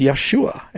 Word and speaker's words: Yeshua: You know, in Spanish, it's Jesus. In Hebrew, it's Yeshua. And I agree with that. That is the right pronunciation in Hebrew, or close as we know.